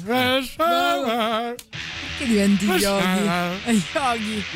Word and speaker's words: Resfeber: In svedese si pronuncia Resfeber.